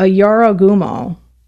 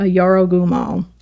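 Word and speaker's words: Jorōgumo: But this is kind of based on a Japanese folklore monster, I guess, for lack of a better term, a Yorogumo, J-O-R-O-G-U-M-O, a Yorogumo, I believe is how you pronounce it.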